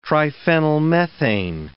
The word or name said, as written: triphenylmethane